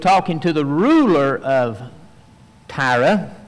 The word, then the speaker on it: Tyre: Now we pronounce that Tyre today. The old King James pronounces it Tyrus, but that is the kingdom of Tyre.